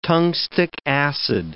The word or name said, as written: tungstic acid